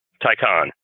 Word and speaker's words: Taycan: I'm going to have you help me. Taycan or Taycan? – Taycan. – Taycan. Okay. I don't know why I have trouble with those Porsche names. Macan, Taycan.